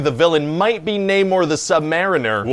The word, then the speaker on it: Namor: Yeah, okay, but first of all, it's pronounced Namor the Submariner.